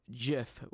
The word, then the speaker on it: GIF: The guy that came out and created these has said that it's GIF, with like pronounced with a J.